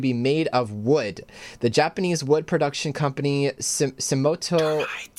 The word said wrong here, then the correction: Sumitomo: I don't know how you, how do you pronounce that, Gertron? – Where are we looking? Where are we looking? I'd say Sumitomo? – Sumitomo Forestry Co.